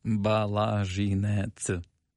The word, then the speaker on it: Balazhynets: Balazhynets.